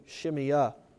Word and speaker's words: Shimei: I've said at times Shimei, I've said at times Shimeah, and I've done some looking on that, on the correct pronunciation. It seems like books have it pronounced different ways, at least the ones I have. But for this morning, I will be saying Shimeah.